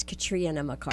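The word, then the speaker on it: Catriona: It's Katrina McCarty. – Katrina. – Right. Like the hurricane.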